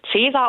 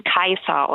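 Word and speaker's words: Caesar: Würden viele Leute Cäsar aussprechen, ich würde ihn Kaiser aussprechen. Warum? Also das C wie ein K und das AE wie ein Ei.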